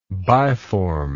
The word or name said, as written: biform